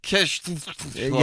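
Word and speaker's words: Ke$ha: Do you pronounce it Kesha even though her S is spelled with a dollar sign? – Kesha.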